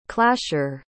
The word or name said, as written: clasher